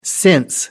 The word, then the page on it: since: American English